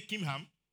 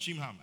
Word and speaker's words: Chimham: It's, say Kimham, not Chimham. I know you don't say Chiham. It's Kimham. The H is silent. Kimham.